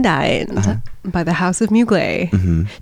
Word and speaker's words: Mugler: When she's like, take me out, this Mugler. And I was like, yeah, that's how you say it.